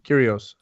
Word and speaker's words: Kyrgios: Did I pronounce that right? You're Kyrgios. – The Greek here. – Kyrgios.